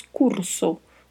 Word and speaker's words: curso: Na sequência temos a palavra curso, Curso que tem significado semelhante ao português, e o que a gente vai ressaltar aí é exatamente a pronúncia, que é diferente. Tem o vibrato da letra R e tem a letra O, que é um pouco mais fechada, né? Então falamos curso, curso.